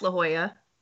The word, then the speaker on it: La Jolla: It's pronounced La Jolla.